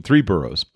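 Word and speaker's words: Tres Burros: It's called Three Burros, you know, with the Spanish pronunciation, Three Burros.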